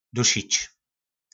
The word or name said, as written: dusic